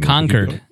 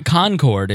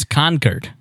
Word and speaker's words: Concord: I think the most perfect moment I've ever had in a city, the city that I liked the most, was Concord, New Hampshire. And I was going to Pork Fest. – You pronounced it wrong. – Concord is how they say it. It's not Concord. It's Concord is how they say it.